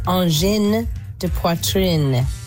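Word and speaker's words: Angine de Poitrine: It's pronounced engin de poitrine. You pronounce the N because there's an E's at the end of those words. Engin de poitrine. Not engin de poitrine.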